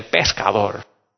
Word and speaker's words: pescador: And Spanish, pecador, is sinner. But if you just pronounce it just a little bit different, you can say pescador.